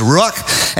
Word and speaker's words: ruach: pneuma or a uh, rock uh, rock, I don't, I'm massacring that pronunciation. Uh, languages is not my specialty. And so in the New Testament, the word for spirit is pneuma. Old Testament, the word is ruach.